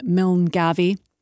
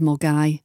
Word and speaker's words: Milngavie: One that I hear often mispronounced by people down south is Milngavie instead of Mulgai.